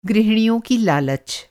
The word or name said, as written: गृहिणी